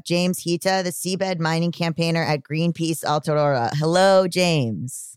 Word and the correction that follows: Aotearoa: Aotearoa, but you did really well.